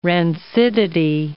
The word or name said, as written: rancidity